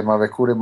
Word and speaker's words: Mavecure: And is that called the Cerros de Maracuer? I'm probably not pronouncing it correctly. Is that the correct location? – Yes, the Mavecure Mountains, Los Cerros de Mavecure, the Mavecure Mountains.